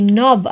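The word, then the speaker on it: knob: नॉब आपके door का नॉब सो के एन ओ बी में अगेन के साइलेंट इट बिकम्स नॉब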